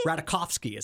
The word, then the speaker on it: Ratajkowski: Ratajkowski? – Ratajkowski is how you pronounce it. It is. She's Polish.